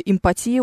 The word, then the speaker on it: эмпатия: Я вынуждена с прискорбием констатировать, что, к сожалению, в слове «эмпатия» ударение падает на букву «и».